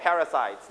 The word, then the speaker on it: parasite: Very small parasites, นะครับ Like in the pictures, นะครับ Very small parasites. Are you done? นักเรียนปลายทางทันไหมครับผมนะ So you stress on the first, second, or third syllable. You stress on first, second, or third syllable. The first syllable, so you can pair louder.